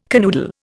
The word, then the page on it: canoodle: Indian